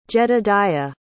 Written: jedediah

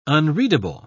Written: unreadable

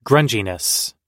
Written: grunginess

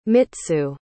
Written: mitsu

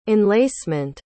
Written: enlacement

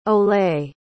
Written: olé

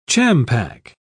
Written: champac

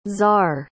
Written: zar

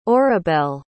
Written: orabelle

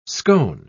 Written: scone

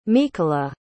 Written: mykola

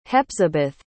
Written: Hepzibeth

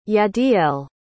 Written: yadiel

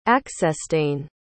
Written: aeccestane

